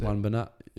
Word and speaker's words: Wan-Bissaka: Was it Wan-Bissaka? How do you pronounce it? – Wan-Bissaka, yeah.